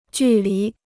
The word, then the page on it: 距离: jù lí